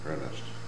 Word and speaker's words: Ernest: That's capital R-E-B-I-L-L-A-R-D, Paul. Is there a middle name? – Ernest. – And would you spell that? – E-R-N-E-S-T.